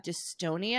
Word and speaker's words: dystonia: So, Jenna, I want to ask you in your bio, I was reading that you have dystonia.